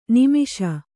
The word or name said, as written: ನೈಮಿಷ